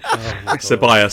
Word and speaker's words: Ceballos: And Willock was taken off for Salibos. – How do you pronounce it? – Ceballos, sorry.